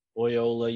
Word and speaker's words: Loyola: And I believe it's Loyola, right? Is that how it's pronounced? – Loyola, yeah.